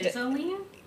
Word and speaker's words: Ghislaine: And then their sister, and her name is spelled G-H-I-S-L-A-I-N-E. And Giseline? – Kind of, that's how I would want to say it, but they were, like, pronouncing it as in, like, Giselle, but we're just going to call her G.